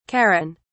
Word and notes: charon: British English

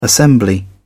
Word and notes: assembly: British English

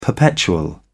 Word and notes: perpetual: British English

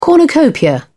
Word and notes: cornucopia: British English

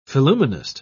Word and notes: phillumenist: British English

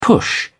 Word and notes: push on: British English

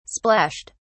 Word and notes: splashed: British English

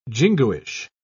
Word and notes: jingoish: British English

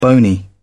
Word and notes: bony: British English

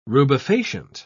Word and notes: rubefacient: British English

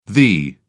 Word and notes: thee: British English

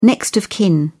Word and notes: next of kin: British English

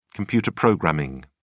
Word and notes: computer programming: British English

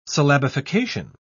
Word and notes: syllabification: British English

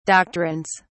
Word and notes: doctrines: British English

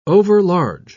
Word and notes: overlarge: British English

